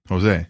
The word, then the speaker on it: Jose: J-O-S-E. How do you say it? – Jose. – Nope, it's Jose. – Oh. – It's Jose Aldo.